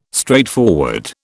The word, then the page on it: straightforward: British English